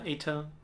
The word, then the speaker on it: Eitr: Äh, wird E-I-T-R geschrieben, also wie Aether. Nein, Aether. Aber Aether ausgesprochen. Laut dem Entwickler.